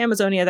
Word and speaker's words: Amazonia: And I say Amazonia that way because it's spelled capital A M A capital Z Onia.